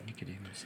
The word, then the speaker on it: Nicodemus: In the 5th century, the Gospel of Nicodemus, I hope that's how it gets pronounced, I didn't look it up. – Nicodemus, okay, yeah.